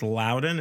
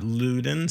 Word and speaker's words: Loudoun: And that is how you say it. I promise it's Loudoun. It's not Loudoun.